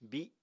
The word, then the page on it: bíi7: apatani